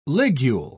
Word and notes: ligule: British English

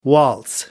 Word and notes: waltz: American English

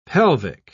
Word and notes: pelvic: British English

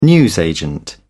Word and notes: newsagent: British English

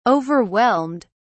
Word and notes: overwhelmed: British English

